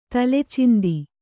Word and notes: ತಲೆಚಿಂದಿ: Kannada

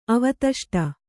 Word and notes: ಅವತಷ್ಟ: Kannada

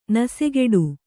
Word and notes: ನಸೆಗೆಡು: nasegeḍu; Kannada

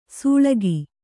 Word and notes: ಸೊಳಗ: Kannada

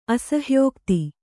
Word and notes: ಅಸಹ್ಯೋಕ್ತಿ: asahyōkti

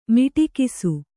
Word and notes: ಮೊಟಕಿಸು: Kannada

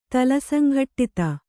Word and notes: ತಲಸಂಘಟ್ಟಿತ: tala saŋghaṭṭita

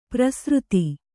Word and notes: ಪ್ರಸೃತಿ: prasřti

